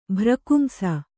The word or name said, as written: ಭ್ರಕುಂಸ